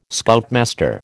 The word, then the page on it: Scoutmaster: American English